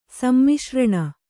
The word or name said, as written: ಸಮ್ಮಿಶ್ರಣ